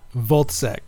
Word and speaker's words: Wozzeck: yeah, Wozzeck.